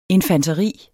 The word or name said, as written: infanteri